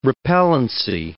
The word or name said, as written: repellency